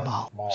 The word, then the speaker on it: Mahler: And how do you say, is it Mahler or Mahler? How do you say that? – Mahler.